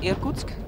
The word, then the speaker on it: Irkutsk: I think it's called Irkutsk.